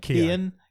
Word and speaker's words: Keoghan: Keoghan.